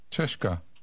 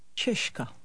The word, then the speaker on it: Češka: The new sound is like the English sh in short. Repeat. – Ška. Ška. Ška. – And here's the whole word, Czech. Listen and repeat. – Češka.